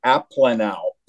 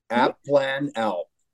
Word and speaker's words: Aplanalp: The last name is Aplanalp. Aplanalp. – Yeah, I love it. – Yeah, well, I was in the third grade before I learned how to say it.